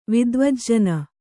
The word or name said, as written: ವಿದ್ವಜ್ಜನ